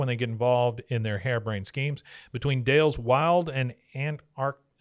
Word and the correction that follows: anarchist: How do you say that? Anarchist. He's an anarchist.